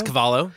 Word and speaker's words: Cavallo: But is it Rob Cavallo? – You guys, I think it's Cavallo.